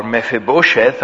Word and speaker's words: Mephibosheth: The next chapter, for example, chapter 9, deals with David's kindness to Mephibosheth, or Mephibosheth, as some people like to pronounce it, in connection with his love for his friend Jonathan.